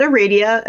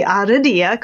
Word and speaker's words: Aradia: Some people might pronounce it Aradia, and some people might be Aradia, because it's Italian.